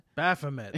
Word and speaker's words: Baphomet: How you Baphomet? – Baphomet.